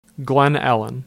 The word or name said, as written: Glen Ellyn